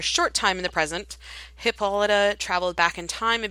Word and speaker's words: Hippolyta: Is it Hippolyta? Hippolyta? – Hippolyta. – There you go. Hippolyta? That sounds better.